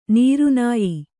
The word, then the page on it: ನಿರ್ನಯ: nirnaya